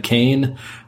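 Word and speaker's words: Koehn: You can find me on the Twitter machine at Brad Kane, or with my name on the Facebook. And of course, my last name is spelled exactly like it sounds. Kilo Oscar Echo Hotel November.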